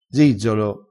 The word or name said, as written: zizzolo